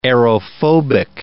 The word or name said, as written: aerophobic